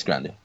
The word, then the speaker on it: Grande: That's how you say her name. I say Grande. It's like Grande. It's Grande, right? – Yeah, it's Grande.